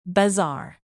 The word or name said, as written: bazaar